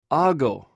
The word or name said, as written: agw